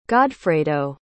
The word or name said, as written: godfredo